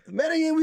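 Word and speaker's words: Medellín: Medellin, we coming, baby. Let's go. – The Medellin takeover. – The Medellin. I think I've been saying it wrong the whole time. Medellin.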